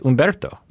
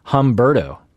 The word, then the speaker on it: Umberto: And, I have this experience with a lot of people, people like Umberto, you know, like if you pronounce it like an American, it's Humberto.